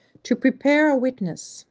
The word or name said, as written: To prepare a witness